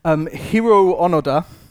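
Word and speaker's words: Hiroo Onoda: Hiro Onoda, that's the correct pronunciation, was a soldier stationed in the mountains on Lubang Island, which is in the Philippines.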